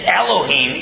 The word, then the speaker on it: Elohim: Because the word in the Hebrew for God is Elohim. If it were singular, it would be El, E-L. If it were dual, meaning two gods, it would be Elah, E-L-A-H. But instead it's Elohim, E-L-O-H-I-M.